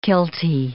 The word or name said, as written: kiltie